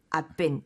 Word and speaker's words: I've been: 이때도 I've been 이 말도 말씀드려야겠네요. – Right. I've been. – I've been. – I've been. – I've been. – 그냥 빨리 발음하면 V가 안 들려요.